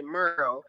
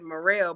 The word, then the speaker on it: Morrell: So they pronounce it Merle, but I was like, no, that doesn't sound right. I said, Morrell, put some pizzazz on it, you know, make it fancy.